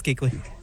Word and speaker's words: Kuechly: And then Craig and Kuechly. Is it Kuechly? Is that the way you pronounce it? – Yeah, it's Kuechly.